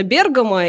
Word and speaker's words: Бергамо: Ты знаешь, я тоже так всегда думала, но Википедия утверждает, что именно Бергамо, и, кстати, наш корреспондент в Италии тоже говорит именно с таким ударением, поэтому я решила себя по поправлять ну, постоянно.